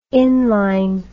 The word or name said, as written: inline